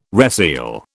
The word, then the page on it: reseal: British English